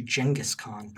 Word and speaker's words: Genghis Khan: But there are also guys like Genghis Khan, it should be pronounced actually Genghis Khan,